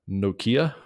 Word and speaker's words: Nortje: it's spelled more like Henrik Nortje, but his last name is actually pronounced more like Nortje, N-O-R-T-J-E.